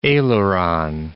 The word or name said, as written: aileron